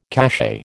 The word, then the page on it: cachet: American English